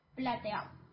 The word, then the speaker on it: Plateau: Ya sé que es difícil pronunciar Joseph Plateau, pero así se llama, Plateau.